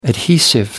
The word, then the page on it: adhesive: American English